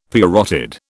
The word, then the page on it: parotid: British English